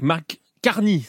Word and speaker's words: Mark Carney: Avec Mark Carney, c'est comme ça que l'on prononce.